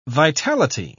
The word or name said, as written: vitality